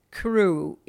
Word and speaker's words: krewe: Now, we should designate for listeners, crew here in the New Orleans context is spelled K-R-E-W-E, and that refers to those magnificent floats we see in Mardi Gras parades.